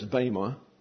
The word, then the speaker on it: bema: For we must all appear before the judgment seat, and the word there is bema, and that's a long E in Greek, therefore pronounced as a long A.